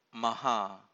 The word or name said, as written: maha